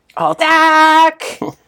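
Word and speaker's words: alt-ac: Alt-ack! Alt-ack, when you say it, you can say it like Aflac commercials.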